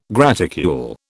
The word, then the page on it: graticule: British English